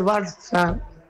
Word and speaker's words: Barça: Y es Barça, pero se escribe Barça, con C de casa. Pero se pronuncia S, esa C en catalán se pronuncia S.